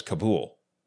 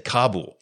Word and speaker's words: Kabul: went into Afghanistan back in the early 2000s, I had always heard, in all the news broadcasts, they always talked about the capital of Afghanistan was Kabul. It was Kabul, Kabul, Kabul, with the accent being on the last syllable. Then all of a sudden, when the Afghan war started, it suddenly became Kabul, you know, with the accent on the first syllable.